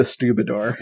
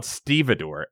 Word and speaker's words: stevedore: You brought this up before, but I think you called it a Stubidor. – Well, it's spelled fucking weird like that. Stubidor. – Oh, I've heard of Stubidor. – Hold on, let me look it up. It's spelled Stevedore. Stevedore. Stevedore.